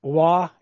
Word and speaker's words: Waugh: There's Billy Waugh. Is that how you pronounce his last name? – Yeah, that's correct.